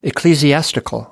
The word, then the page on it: ecclesiastical: American English